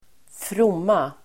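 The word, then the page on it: fromma: [²fr'om:a]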